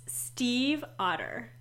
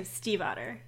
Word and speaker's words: Stiefvater: And I looked up how to say her name because I mispronounced it last week when we were introducing it. It's Stiefvater. – Okay. – So Stiefvater.